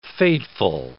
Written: fateful

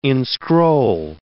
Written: inscroll

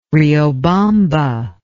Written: Riobamba

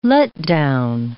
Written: letdown

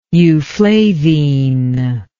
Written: euflavine

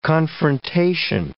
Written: confrontation

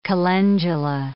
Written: calendula

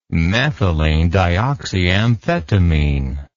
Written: methylenedioxyamphetamine

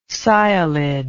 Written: sialid

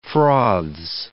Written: froths

